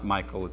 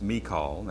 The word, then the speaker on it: Michal: And it's not Michael, it's not Michelle, it's not some other pronunciation. It's Michal, that's her name, M-I-C-H-A-L.